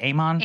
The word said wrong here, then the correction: Ayman: Amen. Amen. – Ayman will continue to be on the air.